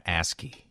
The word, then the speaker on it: ASCII: ASCII is how it's pronounced. – It is pronounced ASCII, and I picked the wrong one because I didn't think it would be ASCII. – Yep, it's ASCII.